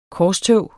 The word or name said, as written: korstog